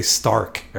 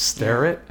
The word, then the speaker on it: Starrett: It sounds like they say Stark every time they say his name, but it's Starrett.